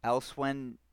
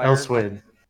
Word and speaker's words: Elsweyr: And then they just released Elsewhen, Elsweyr. – Elsweyr.